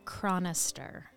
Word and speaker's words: Chronister: Chronister. – Oh, wow. That's bad. – C-H-R-O-N-I-S-T-E-R.